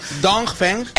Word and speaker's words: Dongfeng: Dongfeng. – XPeng y Dongfeng. – Dongfeng. – Es que si pronuncia mucho la G, al final lo liamos.